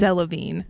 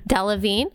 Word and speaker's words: Delevingne: Delevingne. – Delevingne.